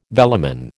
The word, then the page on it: velamen: American English